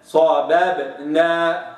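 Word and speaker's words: صببنا: طيب هناك كلمة يعني بها ثلاث باءات وهي كلمة صاببنا، شوف صاببنا، الباء الأولى متحركة بفتح صابا، لابد أنطقها كذا صابا، ما أقولش صاببنا، صاببنا تطلع الباء المتحركة بفتح مثل الساكنة المقلقلة، ولا يقلقل إلا الساكن، صابب كده خطأ، وإنما صابا صاببنا هكذا.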